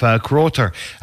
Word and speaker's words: Kroter: And he was taking on a German boxer by the name of Kroter. That's the proper pronunciation from the people I asked yesterday.